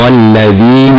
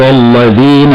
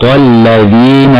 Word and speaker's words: الذين: ونلاحظ هنا أنه لا بد من إخراج طرف اللسان في حرف الذال، هكذا: صراط الذين أنعمت عليهم،